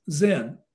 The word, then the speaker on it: Zen: So the graph, that graph in Japan is pronounced Zen. So it's just a pronunciation difference.